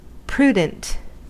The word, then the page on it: prudent: American English